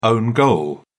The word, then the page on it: own goal: British English